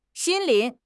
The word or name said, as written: 心灵